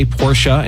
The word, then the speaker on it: Porsche: People are going to say Porsche, but I think it's a two-syllable word, and I think Sia agrees with me.